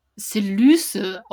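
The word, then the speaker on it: Selyse: Also wir haben ja jetzt, äh, ich habe ja rumgefragt und danke an Steffi, die das Hörbuch hört und die sagt, da wird sie Selüse ausgesprochen, also mhm. Y als Ü macht das ja.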